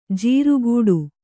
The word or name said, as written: ಜಾರುಗಾಡಿ